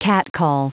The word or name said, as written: catcall